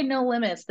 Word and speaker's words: Limbits: So we actually say No Limits. The B is silent, and it's a little bit of a pun because I'm an amputee.